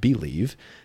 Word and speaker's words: Bleav: That is B L E A V. It's actually pronounced BELIEVE.